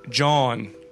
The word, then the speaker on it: jawn: Well, it's spelled J-A-W-N. – Oh. – John.